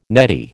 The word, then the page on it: neddy: American English